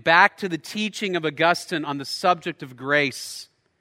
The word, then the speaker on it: Augustine: St. Augustine, right? It's pronounced Augustine is actually the man's name.